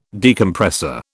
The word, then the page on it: Decompressor: British English